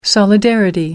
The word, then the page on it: solidarity: American English